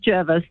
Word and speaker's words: Jervis: The locals say it's Jervis, and we've had quite a few people, even though the GPS will say Jervis and lots of Sydney side as well too. It's the Jervis family from back in the day. That's how they pronounce their name.